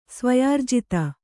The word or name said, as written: ಸ್ವಯಾರ್ಜಿತ